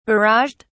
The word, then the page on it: barraged: British English